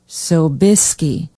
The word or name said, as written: Sobieski